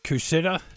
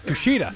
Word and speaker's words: Kushida: All right, I got a couple from Josh writing in, and he's talking about Kushida. I totally butchered that. – Kushida. – Kushida, that guy.